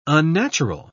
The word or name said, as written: unnatural